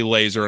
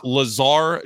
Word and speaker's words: Lazar: Lazar, which was a total letdown. I thought it was going to be Laser, and I thought we were going for an all-name team there, but we'll still spell it that way.